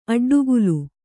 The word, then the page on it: ಅಡ್ಡಗೆಲ್ಲು: kannada